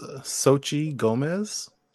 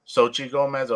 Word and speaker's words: Xochitl Gomez: I believe it's Xochitl Gomez. – Xochitl Gomez, okay.